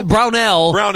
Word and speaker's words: Brownell: Is that how I say his name? – Brownell.